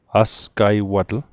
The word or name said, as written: asgaiwatl